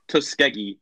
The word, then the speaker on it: Tuskegee: It's spelled Tusk, like animal tusk, like T-U-S-K. Then an E, and then G-E-E. So it's like Tusk, E, Tuskegee.